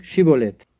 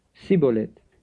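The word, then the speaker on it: Schibboleth: Alors il lui disait, dis donc Chibolette. Mais il disait Cibolette, car il ne pouvait pas bien prononcer.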